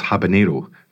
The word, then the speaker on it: habanero: And also, it's jalapeno, but it's habanero, because we don't have that little squiggly line above the N. Not because of that. That represents how it is pronounced.